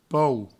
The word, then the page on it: paur: pou; provencal